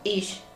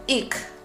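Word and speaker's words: ich: Yo vivo en el norte de Alemania y lo aprendí como ish, pero en otras partes de Alemania se pronuncia algo así como ik, pero eso va a depender mucho de la zona en Alemania en la que vivas. En general, ish, yo.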